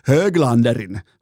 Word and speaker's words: Höglander: Miller, Brady Kachuk, Kirill Kaprizov, joten tämä antaa mun mielestä aika erikoistakin osviittaa Höglanderin suoritustasosta, tai siis Höglanderin suoritustasosta, ei missään nimessä voi ääntää jenkeittäin, vaan pitää ääntää niin kuin skandinaavilaisittain. Näin, huomaatteko, miten vaikea sanoa, tulee silti, vaikka on kurkku aivan, ei ole niin kuin kipeä, tämä kurkku ei pätkän vertaa kipeä.